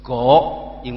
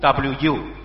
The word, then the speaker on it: 吴: 我姓吴，但是马来西亚的亲戚他们是姓国，因为是福建话的发音。在香港的我们姓是广东话发音的。在中国大陆的他们姓吴 WU 是用普通话发音的。